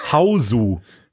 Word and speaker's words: Hausu: Ähm, es geht um den japanischen Film von 1977, Hausu. Wie schreibt man das? H, also wie Haus, ne, auf Deutsch, ja. mit U von 1977.